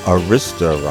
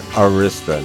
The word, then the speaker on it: Arista: He goes into great detail on how you pronounce Arista. And it is Arista, not Arista. No, it's Arista. Why do I keep messing that up? It's Arista with, with a heavy A sound, is in aristocracy.